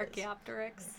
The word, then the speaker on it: Archaeopteryx: Archaeopteryx with the little feathers. Fuck off! Don't you dare start. yeah. you actually uh, pronounced that correctly.